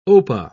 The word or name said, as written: opah